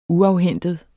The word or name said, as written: uafhentet